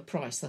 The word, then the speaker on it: Price: There's so many ways. Yeah, there's P-R-Y-C-E, there's P-R-Y-S-E, but I'm a P-R-I-C-E, the normal, the Price is Right.